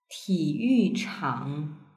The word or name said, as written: tiyuchang